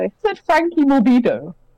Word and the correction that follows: Franco Morbidelli: Morbidelli. – Morbidelli. – Franco Morbidelli.